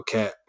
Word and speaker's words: Kat: There we go, Kat. – Goodness gracious. – Cat rhymes with that.